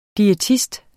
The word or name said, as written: diætist